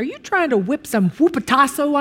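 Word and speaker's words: hupotasso (ὑποτάσσω): Our English word submit is translated from the Greek word, and believe it or not, this is pronounced hupotasso. Hupotasso.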